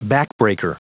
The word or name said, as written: backbreaker